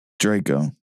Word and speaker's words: Draco: Is it Draco or Draco? – Draco.